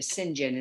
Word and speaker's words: St. John: Right now I'm reading one on Lilias Trotter that was actually written by Patricia St. John, or Patricia St. John is really how you pronounce the name.